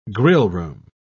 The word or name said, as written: grillroom